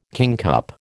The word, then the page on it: kingcup: American English